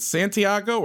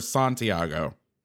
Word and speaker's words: Santiago: I guess it's like potato, potato. I mean, I say Santiago. I've heard Santiago. It's one of those weird, I guess, wherever you're from, that's how you say it.